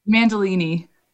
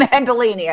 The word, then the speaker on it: Mandolini: Mandolini. – I was going to say that. Alexi Mandolini.